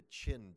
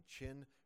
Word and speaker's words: Qin: The first is the Qin dynasty. Qin is spelled Q-I-N. And it's not pronounced quite Qin, but that's close enough for our purposes.